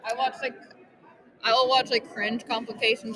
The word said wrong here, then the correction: compilations: with these. I just believe I said that wrong. – Compilations. Whatever.